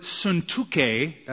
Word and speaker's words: Syntyche: The word suntuke, that's how you say it in the Greek, means fortunate.